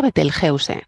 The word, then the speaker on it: Betelgeuse: Y nosotros, según la Fundeu Rae, dice que se pronuncia Betelgeuse, como suena, y que decir Betelgeuse o Betelgeuse es pronunciarlo a la inglesa y no tenemos por qué, porque tampoco no es una palabra inglesa, como has dicho muy bien, es árabe.